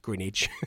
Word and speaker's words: Greenwich: And if you're from that part of the world, that's how you pronounce it, Greenwich. G-R-I-N-I-C-H.